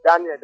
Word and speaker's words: Daniel: Daniel. – I tell you say your name like John just now. – No, my name is Daniel.